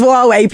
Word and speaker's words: Huawei: "It's Huawei." And I was like, oh, God, okay, I'm sorry. And ever since then, I'm like, it is Huawei, people. Don't pronounce it wrong.